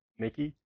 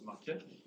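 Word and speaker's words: Makie: Okay, so we're going to use this a beautiful package called WGL Makey. Makey? Makey? – I think it's pronounced Makia. Makia? – Yeah, the Japanese word.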